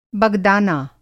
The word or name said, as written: बगदाना